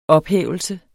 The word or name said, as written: ophævelse